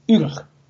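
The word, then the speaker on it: yrch: Por ejemplo, el plural de org, que es orco, es urg. Orcos. Lo que pasa es que en Sindarin se escribe con Y, pero se pronuncia U.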